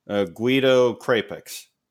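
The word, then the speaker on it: Guido Crepax: Guido Crapix. Correct? – Mm. Yeah.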